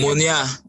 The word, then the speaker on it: Munir: M-U-N-I-R. I don't know how to pronounce his name. – Munir.